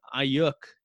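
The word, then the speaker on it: Aiyuk: And then moving on to their next pick in Brandon Ayuk, if I said his name correctly.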